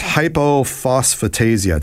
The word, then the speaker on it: hypophosphatasia: What is hypophosphatasia? Did I get that right? – Yes.